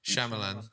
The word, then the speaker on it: Shyamalan: How do you pronounce his name? – Shyamalan.